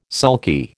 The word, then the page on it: sulky: American English